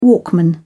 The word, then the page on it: walkman: British English